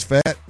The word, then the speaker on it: Pfaadt: That P is silent. – I ain't got it. Oh, is it? So it's fat? P-F-A-A-D-T.